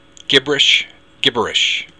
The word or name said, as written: gibris